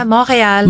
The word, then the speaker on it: Montreal: Is it that's how you pronounce it? Yeah. – Yeah, Montreal. – Montreal. – Montreal in English.